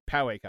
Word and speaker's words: Poway: Poway. That's how you say it. Poway, California.